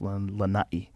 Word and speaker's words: Lanai: So in the island of Lanai, you got to say it right. I usually say Lanai, but it's Lanai.